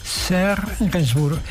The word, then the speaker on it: Serge Gainsbourg: Escrito por Serge Gainsbourg. ¿Lo pronuncio bien? Serge Gainsbourg, perfecto.